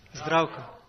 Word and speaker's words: Zdravko: Zdravko. – Zdravko. You don't pronounce the Z before it?